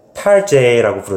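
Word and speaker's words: Target: 쇼핑 센터인 것처럼, 그 백화점인 것처럼 탈제라고 부르더라고요. 그러니까 타겟을 프랑스식으로 발음한.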